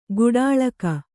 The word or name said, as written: ಕೋಟಲಿಗ